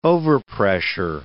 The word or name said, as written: overpressure